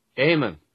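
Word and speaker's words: Eamon: So it's E-A-M-O-N. And here is the correct pronunciation. – Aemon is the Irish form of Old English Aed Rich and Mund Guardian, and implies guardian of the riches.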